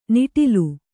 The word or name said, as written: ನಿಟಲ